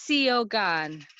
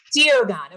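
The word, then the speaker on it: Suo Gân: One of my favorites was Suo Gan, which I'm probably horribly mispronouncing. – Oh, no, you're really close to Siogan. – Siogan.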